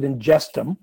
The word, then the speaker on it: Ingestum: So basically what Ingestum is, and you pronounce it Ingestum,